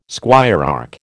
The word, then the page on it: squirearch: American English